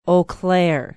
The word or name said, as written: Eau Claire